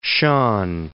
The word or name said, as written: Shahn